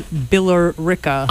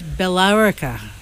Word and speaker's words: Billerica: Or as my GPS says, Billerica. – I am stunned that Kmart is even doing Black Friday deals. – The pronunciation is Billerica.